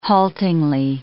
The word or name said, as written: haltingly